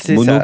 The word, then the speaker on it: Mowno: Mono qui s'écrit M-O-W-N-O.